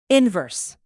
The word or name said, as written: inverse